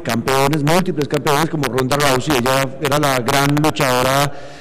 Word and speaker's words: Ronda Rousey: Ronda Rousey, sí.